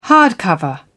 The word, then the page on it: hardcover: British English